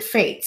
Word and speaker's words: F8: He pronounced it Fate.